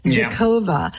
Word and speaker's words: Xocova: Zocova. – Okay, so that was my next thing. It is spelled like this, listeners. X-O-C-O-V-A. And I got the COVA part. I was like, well, that's for COVID. Zocova.